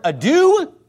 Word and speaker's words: ado: Now, look, somebody who doesn't know the English word ado, A-D-O is pronounced ado. Somebody who pronounces it ado, and he pronounced it in multiple interviews, multiple debates, he pronounced it as ado. Doe, a deer, a female deer, ray, a drop of golden sun.